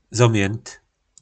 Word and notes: Zomjynt: po našimu

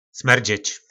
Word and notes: smerdzec: po nasimu

